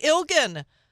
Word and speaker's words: Illgen: Chrissi Illgen with a hard G.